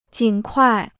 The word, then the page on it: 尽快: jìn kuài